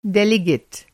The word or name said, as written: डेलिगेट